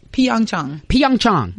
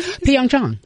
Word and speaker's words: Pyeongchang: How do you pronounce it? – Pyeongchang. Yes. – Okay, see, you got just rolled right. Pyeongchang, 2018.